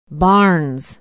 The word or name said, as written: barnes